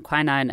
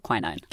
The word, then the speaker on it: quinine: Before we go any further with the cinchona tree and quinine and tonic water, we need to have a language moment. I'm American. I pronounce the word quinine. – Kim is British, like me, and we say quinine.